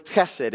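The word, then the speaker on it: chesed (חסד): It's a Hebrew word, chesed. It's one of those Hebrew words that you kind of have to drink some milk so you can say it correctly, chesed.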